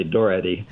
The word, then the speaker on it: Daugherity: I said it rhymes with authority, but it's really Doherty.